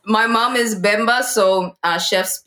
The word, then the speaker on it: Chef 187: is it Chef or Chief? – Chef. – Chef 187. – Yeah.